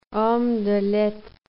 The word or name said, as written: homme de lettres